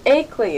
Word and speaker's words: Ackley: Have you ever heard of the I think it's pronounced Ockley Finishing School? – What was that? – Ockley Finishing School? Ockley Hall? – Ackley, is that it? – Oh, yes.